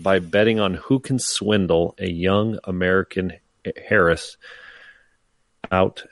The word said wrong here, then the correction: heiress: Like is it heir? Harris? Like an heir to the throne? So it's an heiress. – Heiress. So the H is silent.